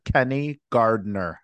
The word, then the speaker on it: Kenny Gardner: And debuting on the show, I've got actor, director, and producer Kenny Gardner.